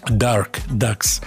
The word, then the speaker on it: Dark Ducks: И от вас пришло сообщение, где упоминается японский квартет Duck Ducks. Dark Ducks, если уж так правильно произносить.